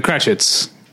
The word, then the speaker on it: Cratchits: Yeah, but the Cratchits.